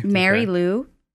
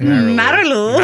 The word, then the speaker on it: Marilou: No, it's Marilou. – Marilou. – Well, look at it. Look at it. – Marilou. – Have you seen Mary Lou pronounced that way? No, that is spelled weird. – So maybe it is Marilou.